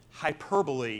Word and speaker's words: hyperbole: Hyperbole is the way you pronounce it.